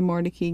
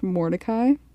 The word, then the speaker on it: Mordecai: Like, before we got there, we thought it was pronounced Mordecai. – Mm-hmm. – Because, you know, that's spelled. – That's exactly how it's spelled. Yeah, Mordecai. But she told us that it was originally pronounced Mordecai, and then it changed pronunciation when the family moved to the south, which is interesting.